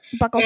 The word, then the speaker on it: buccal: Am I saying it right? Okay. – Yeah. Buccal fat pad excision. Yeah.